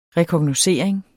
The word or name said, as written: rekognoscering